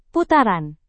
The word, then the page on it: putaran: Indonesian